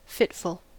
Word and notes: fitful: American English

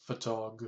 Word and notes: photog: American English

